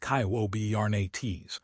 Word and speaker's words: Kubernetes: Traditional tools like Kubernetes, pronounced KYOBERNETES, are struggling with the bursty, compute-heavy requirements of autonomous agents.